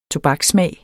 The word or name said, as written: tobakssmag